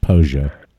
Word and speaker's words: Pollo: Pollo. That's pronounced Pollo.